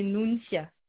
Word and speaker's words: Nuncia: Et le prénom, c'est Nuncia. – D'accord. Ça s'écrit comment, votre prénom, s'il vous plaît? – N-U-N-C-I-A.